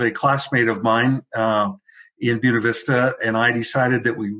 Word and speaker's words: Buena Vista: I grew up in Buena Vista, which is the correct way to pronounce that. My family homesteaded there back in the 1880s, so we have a lot of history there.